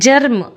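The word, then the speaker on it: germ: ಜಗೆ J letter ಬರುತ್ತೆ, ಆದರೆ ಒಂದೊಂದು ಸಲ ಜಗೆ ಜಿ ಕೂಡ ಬರುತ್ತೆ ನೋಡಿ. ಜರ್ಮ್ ಇಲ್ಲಿ ಜೆ ಬಂದಿಲ್ಲ, ಜಿ ಬಂದಿದೆ, ಜರ್ಮ್ G E R M.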